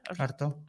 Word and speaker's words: Artaud: Artaud, ¿cómo se dice? – Artaud, Antonin Artaud.